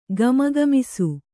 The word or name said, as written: ಘಮಘಮಿಸು